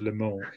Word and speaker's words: Le Mans: You don't actually have to say the S of Le Mans either. You only pronounce the S if it's got a vowel.